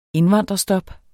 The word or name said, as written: indvandrerstop